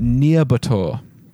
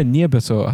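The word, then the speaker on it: Nyírbátor: So we are going to go back to a town called Nyarbator, which is spelled Nyirbator, in 1567. And this was as dark as a place as any in the 1500s. – That was a solid pronounce of that, by the way. The pronunciation was on point. – Really? – I don't know if it was right, but it sounded right. – Yeah, Nyarbator. I'm pretty sure that's right.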